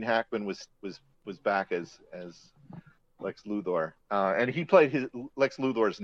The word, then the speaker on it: Luthor: I think one of the better things to happen towards the end of the show was their introduction of John Cryer as Luthor. And well, some pronounce it Luther, but I still remember the original 1960s Superman cartoon that always pronounced it that way, and that's gotten into my head, so you know.